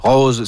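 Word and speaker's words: Rau_Ze: Rose, c'est le duo formé de Rose Perron et Félix Paul. Ça s'écrit R-A-U, barre en bas, Z-E, et c'est très, très bon.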